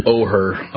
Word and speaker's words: Oher: So The Blind Side, if you haven't seen it, it's about Michael, I would say, is it Oler or Or? – Or. Yeah. It's spelled O-H. – Oher. – Oher, yeah, O-H-E-R.